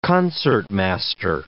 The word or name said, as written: concertmaster